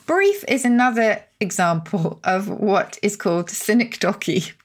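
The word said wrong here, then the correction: synecdoche: Sorry, I'm laughing because I mispronounced this earlier, so you're hearing the edited version. So it's sinecdoche, and it's a figure of speech in which one part is made to represent the whole.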